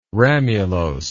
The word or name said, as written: ramulose